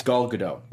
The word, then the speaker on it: Gal Gadot: It's Gal Gadot. – No. Okay. – Yeah. – No. Okay. First of all, the last name, she very clearly on Kimmel talks about the T pronunciation at the end.